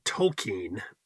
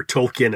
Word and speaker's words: Tolkien: We were looking the other day, and I found this on Tolkien, correctly pronounced, or Tolkien as we mostly pronounce it.